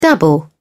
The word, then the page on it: dabble: English; British English